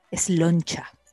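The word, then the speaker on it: sláinte: Salud, cuando uno choca los vasos, en gaélico irlandés es sloncha. Sloncha. Solo Dios sabe cómo se escribe, pero sí, es Claro, cómo se escribirá.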